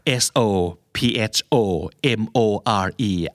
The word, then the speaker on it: sophomore: s o p h o m o r e อ่านว่า sophomore แทบไม่ออกเสียงตัว f o เลย